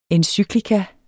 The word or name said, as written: encyklika